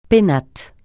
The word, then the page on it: pénates: francais